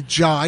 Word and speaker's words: Jai: You didn't say Jai, you say Jai.